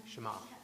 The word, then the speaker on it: Shema: Shema.